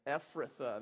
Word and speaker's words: Ephrathah: Quoting directly from the Lord here, he says, But you, O Bethlehem Ephrathah, which is the worst word to pronounce out loud together. Should we all do it together? Ephrathah. There it is. So many THs.